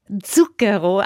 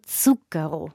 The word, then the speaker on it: Zucchero: Zucchero. Also mir fällt selbst immer wieder schwer, aber so ein weiches Z vorne mit so einem Mini-D, also Zucchero. – Zucchero. – Zucchero.